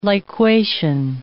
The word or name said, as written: liquation